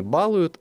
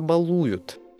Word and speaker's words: балуют: Раем не балуют, а балуют. Глагол «баловать» имеет такие ударения. Балую, балуем, балуешь, балуете, балуемся, балованный, избаловать.